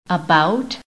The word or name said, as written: ABOUT